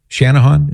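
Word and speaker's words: Channahon: We'll go now to Haiti in, let me see how to pronounce this, Chanahan, Illinois. Chanahan, is that right? – That is correct.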